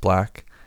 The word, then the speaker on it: 6LACK: But um, and that dude six 6LACK pronounced black, you know that that rapper.